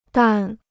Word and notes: 淡: dàn

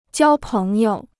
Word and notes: 交朋友: jiāo péng you

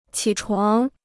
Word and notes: 起床: qǐ chuáng

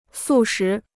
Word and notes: 素食: sù shí

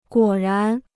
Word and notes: 果然: guǒ rán

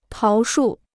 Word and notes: 桃树: táo shù

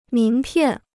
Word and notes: 名片: míng piàn

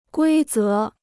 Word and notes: 规则: guī zé

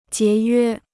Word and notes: 节约: jié yuē

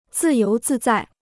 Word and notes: 自由自在: zì yóu zì zài

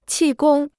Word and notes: 气功: qì gōng

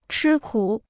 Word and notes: 吃苦: chī kǔ

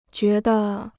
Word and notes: 觉得: jué de

